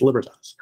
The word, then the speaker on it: Libertas: It's actually pronounced Libertas, but the phonetic spelling is L-I-B-E-R-T-A-S. It's Libertas. You can find me there, or you can find me on Twitter.